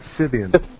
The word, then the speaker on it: Scythian: It's Scythian.